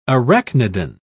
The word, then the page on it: arachnidan: British English